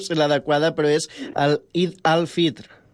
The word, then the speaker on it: Eid al-Fitr: Fonèticament la I és una I, però s'escolta perfectament Eid al-Fitr, que és el nom oficial religiós d'aquesta festivitat. El Fitr és el dijuni, és trencar el dijuni, i Eid és la festa. I llavors, sí, està ben pronunciat.